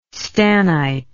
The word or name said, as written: stannite